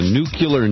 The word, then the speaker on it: nuclear: One point in his favor, he actually knows how to properly pronounce the word nuclear. And, John, I can't tell you how many intelligent people, military officials who served, in the nuclear navy, can't pronounce a simple three-syllable word.